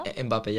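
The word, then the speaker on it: Mbappé: Mbappe, yeah.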